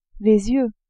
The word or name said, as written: yeux